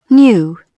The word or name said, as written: new